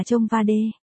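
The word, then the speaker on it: Trông Wa Đê (청와대): Phủ Tổng thống Hàn Quốc hay còn được gọi là nhà xanh mà theo tiếng Hàn đọc là Trông Wa Đê.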